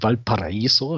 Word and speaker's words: Valparaíso: Ich selber wohne in oder lebe in Valparaiso, das also ist völlig korrekt ausgesprochen, perfekt.